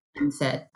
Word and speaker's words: MMFT: There's two big principles upon which MFIT, that's the abbreviation, MMFT, and we pronounce MFIT, is based.